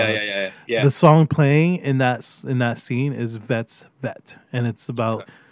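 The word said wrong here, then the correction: Fett's Vette: Fet's Vet. Sorry, I was saying it wrong.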